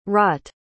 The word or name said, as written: rought